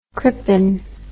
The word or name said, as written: Crippen